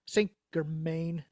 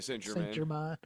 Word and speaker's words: Saint Germain: Saint Germain is how you say it, but it's like Saint Germain.